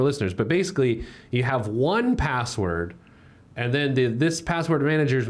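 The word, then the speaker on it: 1Password: LastPass, Dashlane, first one password, however you want to pronounce that. One the number password.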